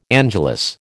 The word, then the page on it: angelus: American English